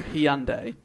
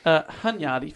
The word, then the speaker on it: Hunyadi: If I could just get back onto Hyundai. – Interesting. Go on. – Hunyadi, fine, we'll call him his name. That's probably mispronouncing it. They'd probably prefer me to say Hyundai, to be honest.